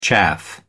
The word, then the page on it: chaff: American English